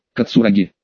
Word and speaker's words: Кацураги: «Намасте», — кивнул я, — «только правильно, Кацураги».